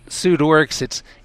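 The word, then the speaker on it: Pseudoryx: It's Pseudorix, it's pseudo-oryx-like. It looks a lot like the antelope called the oryx because of its long, nearly straight, beautifully tapered, sharply pointed horns.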